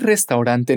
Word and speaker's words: restaurant: En español se dice restaurante, pero en inglés nos comemos algunas letras y decimos restaurant.